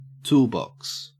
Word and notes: toolbox: Australian English

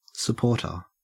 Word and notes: supporter: Australian English

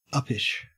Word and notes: uppish: Australian English

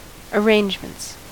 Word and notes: arrangements: American English

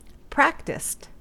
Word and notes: practised: American English

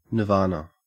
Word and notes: nirvana: Australian English

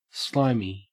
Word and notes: slimy: Australian English

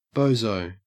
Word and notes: bozo: Australian English

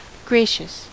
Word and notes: gracious: American English

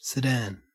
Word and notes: sedan: Australian English

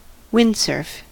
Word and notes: windsurf: American English

